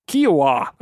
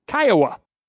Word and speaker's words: Kiawah: First, the unfortunate alligator victim was taken and drowned on Kiawah Island, not, as I stated, Kiowa, which is in Charleston County, not Beaufort County, which is actually pronounced Beaufort.